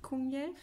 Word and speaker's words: Kungälv: Und der Jöteil teilt sich bei Kungälv. Kungälv. Okay, also ich habe schon gecheckt. Das äh, G spricht man wie ein J. Äh, genau, durch Kunjelf sind ähm, Elisabeth und ihre Freunde auch gekommen.